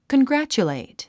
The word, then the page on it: congratulate: kənɡrǽtʃulèit; コングラァチュレイト